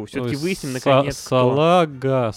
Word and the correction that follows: Салах: Салаг, да, что Правильно говорить Салах. Салах.